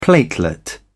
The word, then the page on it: platelet: British English